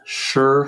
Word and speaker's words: Schurr: It's called Sure High School, like, you know, for sure. But it's spelled S-C-H-U-R-R.